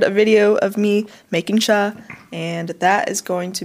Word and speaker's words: chai: Yeah, we pronounce it cha because that's just how you pronounce it. But chai is like the term that everybody knows, so I just put it as chai latte. – Yeah, everyone says chai latte. It's not. It's cha. So we're drinking cha right now. So it's half past cha. That's how I'm always going to pronounce it, but it's spelled chai.